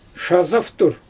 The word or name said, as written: ʃazaftur